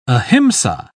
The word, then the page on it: ahimsa: British English